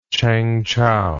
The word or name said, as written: Changchou